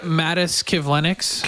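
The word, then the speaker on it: Matiss Kivlenieks: Elvis is represented by Edmonton's Jerry Johansson, as you know, and was involved in the, was it Matisse Kivlinks? Is that how you pronounce? – Yep, Mattis Kivlinks. – Kivlinks, yes.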